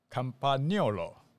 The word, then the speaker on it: Campagnolo: 呃，最早就讲 Campagnolo 啊，然后后来我是去了意大利之后，原来意大利人是 Campagnolo 哦，这是意大利人的念法。